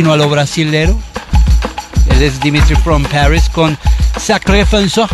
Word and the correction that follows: Sacré Français: Pues, Sacré Français.